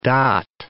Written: dot

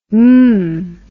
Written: mmm

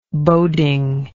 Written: bodingly

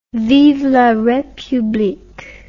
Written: vive la republique

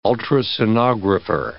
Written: ultrasonographer

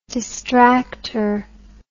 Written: distracter

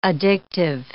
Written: addictive

